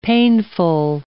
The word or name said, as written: painful